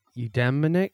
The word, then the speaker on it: eudaimonic: And you mentioned the eudaimonic.